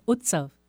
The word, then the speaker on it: Utsav: It's Utsav. – See, it's Utsav, and I thought it was just the letters that represented something, but it's Utsav. And what does that mean? – Utsav means festival.